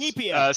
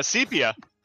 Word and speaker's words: sepia: Coming live from the 1920s, building shots in Septia, which apparently is pronounced Sepia. – Sepia. I just found out.